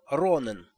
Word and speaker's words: Ронен: Все звали его Ронен, с ударением на первом слоге, потому что Ротем — имя для какой-то давалки, а в Гевате давалок не держат.